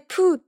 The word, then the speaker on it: 黄熊: 黄色黄色の熊ということで、まさかと思いましたが、これでプーと読みます。